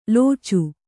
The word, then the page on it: ಲೋಚು: lōcu